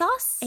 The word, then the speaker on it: ASOS: Since I mentioned it just a moment ago, most of the clothes in Bat that you see are actually bought on like ASUS? I don't know if I'm saying that right. – ASOS? – ASUS. – ASUS. It's an O. Not a U.